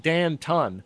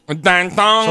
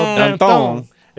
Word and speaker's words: Danton: Now, one close friend I thought I would mention here is this guy named Danton, which is spelled like Dan Tun. But I'm trying to be... fair. – Savvy. – Dan-tong. – So Dan-tong,